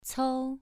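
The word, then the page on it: cou: tone 2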